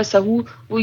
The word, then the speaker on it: सहव: सजदा सहू नहीं होता, याद रखें, बाज लोग कहते हैं सजदा सहू, वो ये लफ्ज सही नहीं, इस तरह से प्रोनाउंसिएशन नहीं है इसकी, सहव होता है,